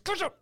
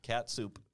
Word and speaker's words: ketchup: She looks at me and she goes, it says catsup. I said, it says ketchup. K-E-T-C-H. Pronounce the tsh. – Yeah, catsup.